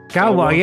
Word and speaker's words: Gauloises: Gauloises, yeah, yeah, yeah.